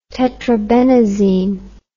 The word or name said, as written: tetrabenazine